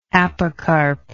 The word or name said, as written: apocarp